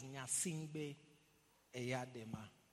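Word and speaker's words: Gnassingbé Eyadéma: In case you don't know how to spell, the name is there. G-N-A, but it's pronounced as Nyasimbe Eyadema. Yeah.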